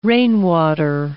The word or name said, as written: rainwater